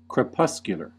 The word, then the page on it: crepuscular: American English